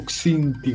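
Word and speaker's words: Xindi: No, esattamente, non è un caetiano, ma, ebbene, è un Xiniti, che è stato visto... – Non si pronuncia così. – Come si pronuncia? Correggimi, te ne prego, non lo so dire. – Si pronuncia tipo Xinti, qualcosa del genere.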